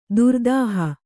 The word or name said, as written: ತಿರ್ದುಹ